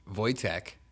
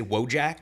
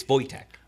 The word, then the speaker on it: Wojtek: Because it's you always want to say Wojak. – Yeah, I want to say spelled. Wojak or Kojak.